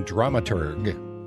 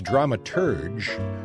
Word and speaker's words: dramaturg: Now, as intrigued as Jim was about the term dramaturg, so was I. Now, it's sometimes pronounced dramaturge, sometimes spelled drama and then T-U-R-G or drama T-U-R-G-E.